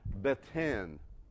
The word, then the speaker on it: beten: Because the word that's used here for heart is this word that's pronounced beten. You'll say that? Beten.